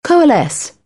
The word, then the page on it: coalesce: British English; əʊ